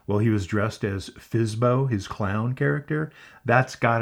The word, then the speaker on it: FSBO: For sale by owner, FSBO, pronounced FISBO in the business.